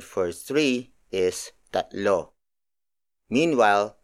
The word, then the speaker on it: tatlo: The next word is tatlo. Tatlo. Tatlo. Tatlo is spelled as T A T L O.